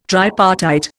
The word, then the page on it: tripartite: Indian